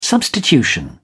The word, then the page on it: substitution: British English